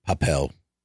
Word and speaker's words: Papel: Casa del Papiro. Yeah. I'm sure I said that right. – It's definitely heist. It's a heist type of movie, but. – Papel. – Yeah. – Papel. – Yep. La Casa de Papel or something.